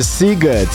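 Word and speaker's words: Sziget: Wcześniej Jazzy i Can't Deny It, bo to jest tak chyba po węgiersku, że szy czyta się jako sy, a sy jako szy, prawda? Bo to jest bardzo ważna rzecz, bo pisze się Siget, a wymawia się Siget.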